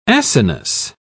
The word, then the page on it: acinus: British English